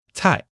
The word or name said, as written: cai4